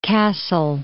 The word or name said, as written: Kassel